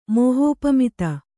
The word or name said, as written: ಮೋಹೋಪಮಿತ